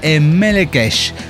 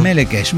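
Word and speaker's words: Melechesh: Se pronuncia Melekesh. Melekesh.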